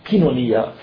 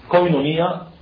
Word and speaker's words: koinonia: Kinonia, communion, common prayer, common breaking bread, teaching of the apostles, also common goods, etc. Indeed, kinonia, or koinonia, as some pronounce it, koinonia or kinonia, communion, may reflect in Greek an early Semitic name for the Christian group, like the Qumran self-designation as Yahad.